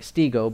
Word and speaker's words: stego: If you're taking notes, S-T-E-G-O, like stego, but it's pronounced, the E is pronounced like an A. S-T-E-G-O, stego.